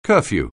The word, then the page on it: curfew: anglais